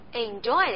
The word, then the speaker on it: enjoy: 对， enjoy 的意思呢是享受。注意这个词的发音，除了我们刚学过的 j，还有一个新面孔 oi。